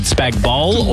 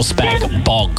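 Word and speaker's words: spag bol: Is it spag bowl or spag bog? – Spag bowl, the G's silent.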